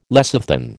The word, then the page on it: lecithin: American English